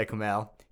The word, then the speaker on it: Kumail: Yeah, Kumail.